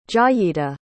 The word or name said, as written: jayeeta